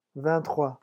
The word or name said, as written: vingt trois